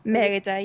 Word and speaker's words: Merida: Yeah, well, I always called it Merida, but John Lasseter calls it Merida, so we'll go with the God's version. Merida, yep.